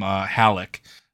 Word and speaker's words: Halleck: But I think we're going to move on to our last question here, which is from Halleck, who told me to pronounce his name like Alec, as in Alec Trebek, but with an H.